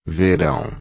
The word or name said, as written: verão